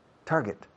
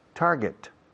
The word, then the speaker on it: target: La pronunciación target no existe. But it's very common for you people to make that mistake. And I ask, my God, how long is it going to take me to change this pronunciation from target, incorrect, to target?